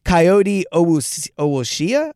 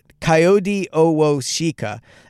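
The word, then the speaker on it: Kayode Awosika: And then their left guard, former Eagle, by the way, Coyote Owoshia, I believe is how you pronounce it. Coyote Owoshika.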